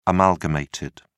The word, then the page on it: amalgamated: British English